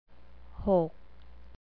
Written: hok

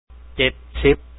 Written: 70

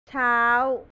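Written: เช้า

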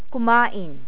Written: kumain

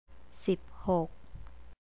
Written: siphok

